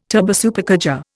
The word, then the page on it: turbosupercharger: Indian